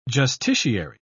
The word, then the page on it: justiciary: British English